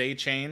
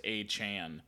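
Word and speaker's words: Achane: We all thought it was Achan because that's what it's spelled like. And then after his big game, he kind of stepped into the spotlight and made sure everybody know that it is pronounced Achan.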